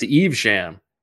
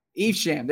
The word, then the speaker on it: Evesham: I think Tracer Bullet says it's pronounced Evesham. – Evesham, there you go.